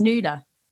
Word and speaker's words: Nuala: but it's pronounced different. So you wouldn't think it with a name like Nuala, but it is just because there's in Ireland it's kind of semi-common, but it's always pronounced Nuala.